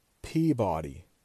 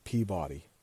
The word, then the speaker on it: Peabody: P-E-A-B-O-D-Y. Peabody. But nobody calls it Peabody. It's Peabody. Peabody, Massachusetts. Again, it's one of those things. I don't know why, but if you live near Massachusetts, you just know that that's how it's said.